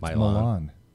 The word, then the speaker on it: Milan: Like living in Ohio next to Milan, Ohio. It's Milan.